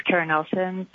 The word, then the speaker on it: Kara Nelson: Hi, yeah, this is Karen Nelson, K-A-R-A-N-E-L-S-O-N.